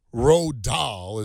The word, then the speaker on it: Roald Dahl: Roald Dahl is how you say his name.